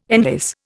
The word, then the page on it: enlace: Indian